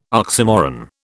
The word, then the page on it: oxymoron: British English